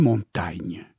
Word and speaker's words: Montaigne: Et je vais vous donner un exemple de gasconnerie ou de gasconnade, c'est que dans mon pays, on ne prononce pas le « i ». Et par conséquent, nous devrions l'appeler montagne.